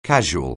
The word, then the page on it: casual: anglais